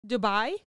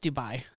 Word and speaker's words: Dubai: Khalifa, Dubai? – It's just Dubai.